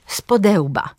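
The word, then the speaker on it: spode łba: Na przykład powiemy patrzeć spodełba. Akcent pada na drugą sylabę przyimka.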